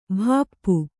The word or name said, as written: ಭಾಪು